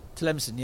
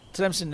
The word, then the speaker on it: Tlemcen: Tlemcen, yeah. We had to look up the pronunciation of that. Tlemcen in Algeria.